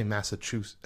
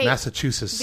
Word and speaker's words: Massachusetts: I can't say Massachusetts. I never pronounce the T. – Okay. – Massachusetts.